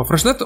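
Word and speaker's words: Freixenet: Freshnet пишется через букву X вместо буквы Ш. Ну, так это читается. И а, дело в том, что а, Freshnet, он очень популярный, но он довольно попсовый.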